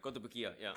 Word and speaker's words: Kotobukiya: I cannot, how do I pronounce it? Kotobukiya? – Kotobukiya, yeah.